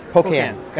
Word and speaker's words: Pocan: Pocan, did I pronounce it correctly? – Yes, Pocan.